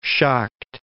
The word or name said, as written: Schacht